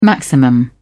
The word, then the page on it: maximum: British English